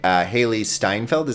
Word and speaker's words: Hailee Steinfeld: I think that the lead, Hailee Steinfeld, is that how you say her name? – Mm-hmm.